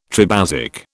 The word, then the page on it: tribasic: British English